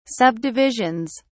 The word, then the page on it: subdivisions: British English